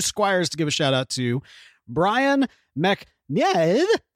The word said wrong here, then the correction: McNevin: Oh, McNevin. – McNevin. Oh, I did mispronounce it. – Yeah. – I didn't even have to try. – You didn't even say a whole syllable. – All right. – It's Brian McNevin.